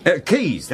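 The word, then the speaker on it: Caius: What's that funny one, the one that doesn't sound like how it's spelt? – Well, you've lost me. – Keys, that's it. – Oh. – Yeah, Keys College, Cambridge.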